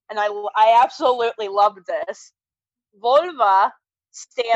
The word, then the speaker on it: volva: Vulva. Not the female part. It's literally V-O-L-V-A. So it's like a Volvo.